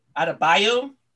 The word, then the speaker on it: Adebayo: Had Durant, Tatum, Green, Booker, Levine, Lillard, Love, Adebayo.